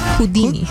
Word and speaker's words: Houdini: Houdini. – Houdini. No se dice así. O sí. – Houdini. Houdini. Houdini. Como el mago, ¿no?